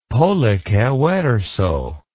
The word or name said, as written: pollice verso